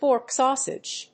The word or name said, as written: pork sausage